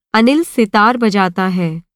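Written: सितार

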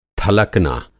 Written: थलकना

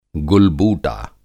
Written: गुल-बूटा

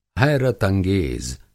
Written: हैरत-अंगेज़

